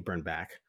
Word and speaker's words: Birnbach: And of course, I do the Blazer Focus podcast with Craig Birnbach, but excuse me, Burnback it's, it's back, and I always say Bach because it's spelled like Bach.